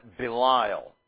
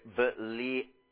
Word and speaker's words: Belial: It's not exactly the way that it is pronounced in Hebrew, though somewhat close. Belial. That is, you have to say Belial. You have to make that short A sound two times right next to each other. We're not used to doing that in English. It's almost like you're saying ah, and then you interrupt yourself and then start again.